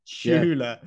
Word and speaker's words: Chula: like Chula. Yeah. Which is actually I, wrong. It's chew like a choo choo train. It's chula. Yeah, but no, to me that's a chula.